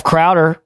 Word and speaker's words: Crowder: Steph Crowder rhymes with chowder.